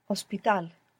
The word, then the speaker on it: hospital: So it's written, the H is written in convertible words, for example, like hospital, ends in A-L. But it's not pronounced. You must imagine that it begins with an O.